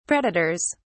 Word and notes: predators: British English

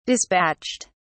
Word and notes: despatched: British English

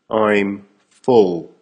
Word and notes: I'm full: American English

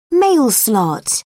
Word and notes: mail slot: British English

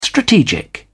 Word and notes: strategic: British English

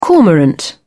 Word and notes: cormorant: British English

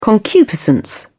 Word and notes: concupiscence: British English

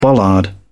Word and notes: bollard: British English

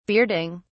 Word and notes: bearding: British English

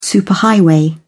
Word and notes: superhighway: British English

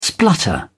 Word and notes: splutter: British English

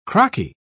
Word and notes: crocky: British English

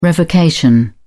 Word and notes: revocation: British English

